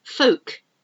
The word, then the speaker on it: folk: C-A-L-M, it's calm. It's calm out today. It's not too windy. It's calm. Folk. Folk, a folk song. You could talk about folk music. F-O-L-K. You don't pronounce the L.